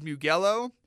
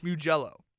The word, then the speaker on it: Mugello: which I mispronounced last time. I thought it was Mugello. It's not. It's Mugello.